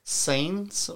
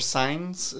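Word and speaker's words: Sáenz: Sains or Signs is the way I've heard it pronounced before that spelling.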